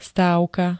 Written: stávka

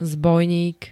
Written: zbojník

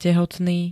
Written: tehotný

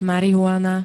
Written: marihuana

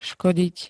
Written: škodiť

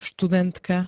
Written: studentka

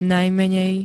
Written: najmenej

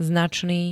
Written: značný

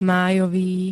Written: májový

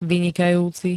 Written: vynikajúci

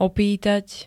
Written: opýtať

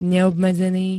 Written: neobmedzený

